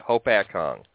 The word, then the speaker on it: Hopatcong: Hopatcong.